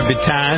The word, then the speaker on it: Bittan: Roy Bittan, is that how you pronounce his name? – Yep. – Or is it Bitten? – No, Bittan.